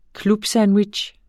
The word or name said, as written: clubsandwich